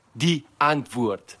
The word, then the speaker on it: Die Antwoord: Ant. Die Ant. Ant. Ant. Like Kant. Then Woord. Die Antwoord.